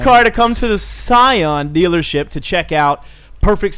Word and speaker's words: Scion: Skion? S-C-I-O-N. Is that a car? – It's a car, yeah. – Well, there's a dealership. – It's a Scion. – Scion, it's pronounced. My bad.